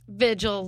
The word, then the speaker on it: Vigil: Vigil, like the word.